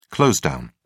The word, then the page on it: close down: British English